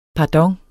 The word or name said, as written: pardon